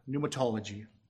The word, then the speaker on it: pneumatology: I had to really practice that word because I pronounce it sometimes pneumatology because I keep forgetting about the P. So pneumatology.